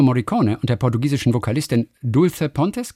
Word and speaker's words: Dulce Pontes: Dulce Pontes.